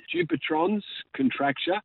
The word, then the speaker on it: Dupuytren's contracture: You have, and I hope I'm pronouncing this correctly, is it dipetrans contracture, more commonly known, of course, as Vikings disease, which is a nasty bloody thing? – It is. Dupuytren's contracture.